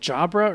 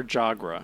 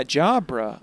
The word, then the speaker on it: Jabra: Wait, is it Jabra or Jagra? – Jabra. B.